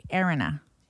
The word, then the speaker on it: Erinna: Erin, she spells it E-R-I-N-N-A. Erina. Oliver's wife. – What if it's Arena? – Spells it because Irina would be with an I. – Irina. I guess Erina, yeah.